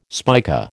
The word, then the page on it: spica: American English